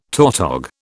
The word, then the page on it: tautog: British English